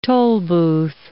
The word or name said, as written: tolbooth